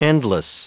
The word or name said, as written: endless